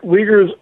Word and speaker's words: Uyghurs: Is that how it's pronounced? – Right. Uyghurs are Turkic Muslims.